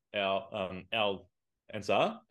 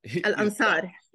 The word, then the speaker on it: Al Ansar: And your score, Al Ansar? – Al Ansar, yes.